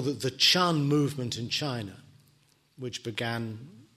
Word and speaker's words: Chan: In fact, it is the Japanese pronunciation of a Chinese character, which is pronounced Chan.